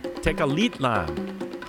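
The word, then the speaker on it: Tecalitlán: Mariacho Vegas to Tenochtitlan. No, I'm sorry, Tecalitlan. Tecalitlan. Ha! I managed to mispronounce it 3 times, and there was a pronunciation guide here.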